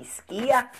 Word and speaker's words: Skyr: You know, Viili and Skia, which spells S K Y R, but they tell me it rhymes with here, so that must be Skia.